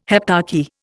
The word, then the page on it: heptarchy: Indian